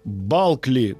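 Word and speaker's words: Bulkley: Маргарет Н. Бакли, Балкли, точнее, Балкли, правильно говорить да.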